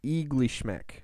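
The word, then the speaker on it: Iglishmêk: It's like Ishklamek. – That's really close. – What is it? – Eaglishmek. – Oh, Eaglishmek. Okay, I just missed. – You just switched around.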